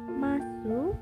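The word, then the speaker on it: mas (ます): Tulisannya masu tapi bacanya mas. Seperti halnya desu bacanya des.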